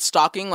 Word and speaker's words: stalking: Oh, do you pronounce the L in stalking? – No, it's just like, I it's a difference between reading and hearing. Because I thought you said stalking, like a sock that is way high.